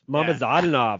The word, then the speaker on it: Mamadzadanov: Zaur Izayev versus Mukhidin Mamadzadanov. – Mamadzadanov. – Oh, yes. There's an extra. There's an extra syllable in there that I missed.